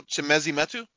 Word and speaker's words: Chimezie Metu: From San Antonio, Chimezie Metu? – Yeah.